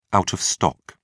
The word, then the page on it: out of stock: British English